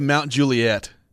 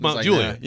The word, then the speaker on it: Mount Juliet: Okay, Dennis says outsiders always pronounce Mount Juliet incorrectly. Mount Juliet? – They always say Mount Juliet. They're like Mount Juliet. – But it's Mount Juliet.